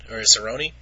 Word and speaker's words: Cerrone: Couple other guys I wanted to touch on that fought this weekend, a couple undefeated guys, and I hope I'm pronouncing his last name correctly, Donald Cerrone? – Cerrone.